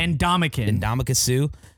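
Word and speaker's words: Ndamukong Suh: Ndamukong Suh.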